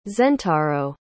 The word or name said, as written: zentaro